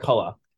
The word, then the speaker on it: McCullough: Well, I like your pronunciation of his name there, JJ McLeod. It's JJ McCullough.